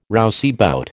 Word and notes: rouseabout: American English

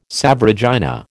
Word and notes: Salve Regina: American English

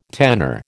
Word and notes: tenor: American English

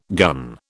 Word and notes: gun: British English

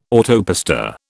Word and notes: autopista: British English

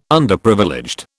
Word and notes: Underprivileged: British English

